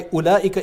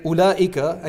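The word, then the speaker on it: Ula'ika (أولئك): It's called Ma'rifat al-Rusum, which discusses why Ula'ika, I don't know if any of you have ever considered this, but why Ula'ika is written with a silent waw. Right? Ula'ika, and you don't say Ula'ika, that waw is silent.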